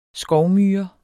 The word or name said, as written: skovmyre